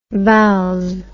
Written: vals

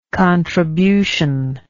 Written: contributional

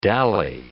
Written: dally

